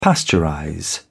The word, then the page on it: pasteurize: British English